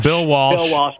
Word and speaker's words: Walsh: So I have Bill Belichick and Tom Brady, Lombardi and Bart Starr, Chuck Knoll and Terry Bradshaw, Bill Welch and Joe Montana. – Bill Walsh. – My bad. Mispronunciation.